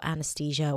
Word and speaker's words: anesthesia: Anesthesia. You might even hear people saying it with a T sound, anesthesia, but usually it's pronounced with a TH.